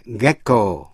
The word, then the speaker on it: gecko: Tuy nhiên có biệt lệ gecko, G-E-C-K-O, gecko là con thằn lằn. Những âm sau đây, G đọc là G. Gown, gown, G-O-W-N là áo choàng.